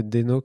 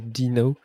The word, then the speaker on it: Deno: Bonjour, j'aime parler de Deno que je suis censé prononcer Dino.